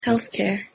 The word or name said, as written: healthcare